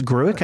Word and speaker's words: Gruik: So this is Gruik, nice. I think is how you say it. G-R-U-I-K.